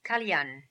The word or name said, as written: Kalyan